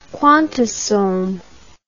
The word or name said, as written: quantasome